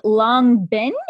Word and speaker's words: Long Binh: It's called Long Binh, is that right? – Yes, yeah.